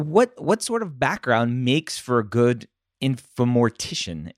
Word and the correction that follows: informatician: yeah, informatician.